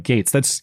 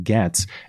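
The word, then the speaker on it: Gaetz: John comes back and he says Gates is pronounced like Gates. You're right. I have seen that. I don't know why that doesn't stick in my mind, because I've heard too many people pronounce it Gets, and it just kind of looks like Gets to me. But yeah, you're right. I have heard him say that it's Gates, even though it's spelled all weird.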